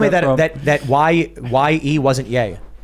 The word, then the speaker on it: Ye: Yeah. – Small yield nuclear weapons, right? – Ivan Ooh says, stop saying yay as ye. Or it's pronounced yay, Kanye West. – Yeah, it's yay, I think.